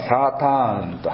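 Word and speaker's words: サ ー ターン (שָׂטָן): とこの、サタンっていう、これヘブライ語ではサーターンと発音しますが、これはもともとはですね、固有名詞ではなくて、非難をするとか訴えるっていう言葉ですね。訴えるものとなりますとサーターンになります。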